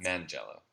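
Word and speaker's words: Manganiello: I thought Joe Mangiello, or however you say his name. That's Mangiello, yes.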